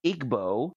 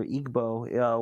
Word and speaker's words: Igbo: Uh, and so actually, that is uh, I believe it's pronounced Igbo or Igbo, uh, one of the language languages or the peoples, native uh, peoples from Nigeria, from southern Nigeria.